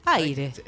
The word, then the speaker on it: Ahyre: Porque dice, ¿cómo lo pronunciamos? ¿Aire? – Aire. – Es aire. Sí. Pero esa H y esa Y... – Tiene un sentido. – Son como una cédula de identidad. – Exactamente. La palabra se escribe A-H-Y-R-E.